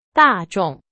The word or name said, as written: 大众